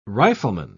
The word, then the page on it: rifleman: British English